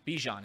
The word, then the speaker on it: Bijan: Bijan, is that how you pronounce it? – Yep.